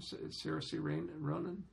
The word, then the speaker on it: Saoirse Ronan: Saoirse Ronan? – Mm-hmm.